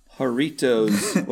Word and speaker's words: Jarritos: And Greg, please tell us what you thought of the pineapple Jarritos. what you say it. You can roll your R's. Jarritos. There you go.